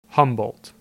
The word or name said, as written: Humboldt